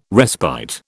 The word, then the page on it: respite: British English